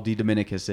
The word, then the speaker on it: DeDominicas: but Paul DeDominicas is? – Yeah, fair enough.